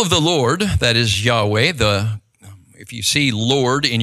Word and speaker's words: Yahweh: and it's capital L and then lowercase caps O-R-D, then that's referring to God's personal name, Yod-Heh, Vav-Heh, we think it is pronounced Yahweh.